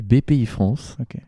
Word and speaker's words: Bpifrance: On dit la BPI France ou BPI France d'ailleurs La BPI, BPI France Alors on dit BPI France. Euh, pour la petite anecdote, c'était BPI, la Banque Publique d'Investissement. C'est long ça. Et euh, ça a été rebrandé en BPI France qui s'écrit, euh, il faut le savoir, avec un B majuscule et tout attaché.